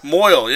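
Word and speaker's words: mohel: They pronounce it mole or mole? – What? – The guy that does the snipping when they get to be teenagers. – Moyle, yeah.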